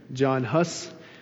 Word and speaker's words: Jan Hus: Many of the early reformers, like Jan Hus, Tyndale, I think I just pronounced it in a Czech manner, John Huss would be the English translation of that, William Tyndale, John Wycliffe, others were persecuted and some burned at the stake.